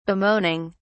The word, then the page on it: bemoaning: British English